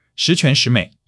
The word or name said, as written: 十全十美